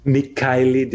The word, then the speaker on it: Michailidis: I think I pronounced your name wrong. Michailidis? Michailidis? – Michailidis is how it's usually pronounced.